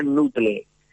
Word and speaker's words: Nootle: Entonces, hay una aplicación que yo recomiendo bastante que se llama Nutle, que se escribe Nootle, ¿vale? Uh-huh. Como de noodles, ¿no? – Sí. – Como de pasta.